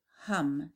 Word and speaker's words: Ham: Ham for the throat chakra. Pronounce the word hum, as in humming.